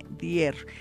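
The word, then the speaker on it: Dyer: También hay otro autor que a mí me gusta que se llama el Doctor Dier, que se escribe D-Y Dier, Dier, así como suena.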